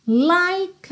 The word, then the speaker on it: like: Like a bridge, like, a like a, porque o E do like não é pronunciado, ok? Então você já junta o K no A, like a bridge, bridge, bridge, ok?